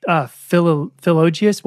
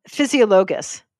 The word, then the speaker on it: Physiologus: And I found this crazy stuff from Philologius. What was that book? How do you pronounce that? – Physiologus.